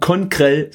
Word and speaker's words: Conkrell: Heute sage ich ganz bewusst nicht, es ist Donnerstag, denn ich habe zu Recht Schelte erhalten auf Twitter von dem guten Conkrell. Habe ich das aus Versehen gerade Englisch ausgesprochen? Conkrell, so ist es richtig.